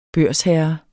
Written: børsherre

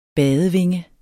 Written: badevinge

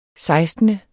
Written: sekstende

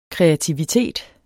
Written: KREATIVITET

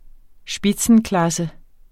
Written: spitzenklasse

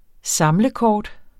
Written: samlekort

